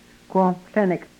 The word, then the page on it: kwomhlenek: yurok